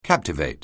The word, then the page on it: captivate: anglais